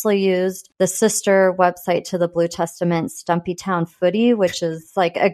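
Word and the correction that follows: Stumptown Footy: Did I say that right? – Stump Town Footy. – Oh, yeah, Stump Town. Did I say Stumpy Town? – Stumpy.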